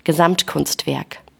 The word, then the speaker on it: Gesamtkunstwerk: And I've always loved that German concept, and I'm going to butcher it, Gesamtkunstwerk. – Gesamtkunstwerk. – Thank you for saying it correctly.